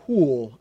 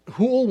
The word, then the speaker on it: Houle: My third example is a study of Zulu Christians by a guy named Robert Houle. I think that's how he pronounces his name, H-O-U-L-E.